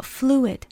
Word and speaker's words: fluid: after flu, you have to add another I sound. Whereas fruit, you can just go directly to the T. – 大家如果是在用中文的字来帮助你发音的话，那么刚刚我们讲到 fluid, 它和录音的录很相似，但是这个单词可不能念成 fluid, 因为在这里 I 也一定要发音，它并不是和 U 连在一起发一个长音，而是自己有它单独的声音。